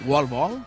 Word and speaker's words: wall ball: Yo creo que está pronunciado. Hombre, no está pronunciado como pronunciaría Chloe, pero es wall ball.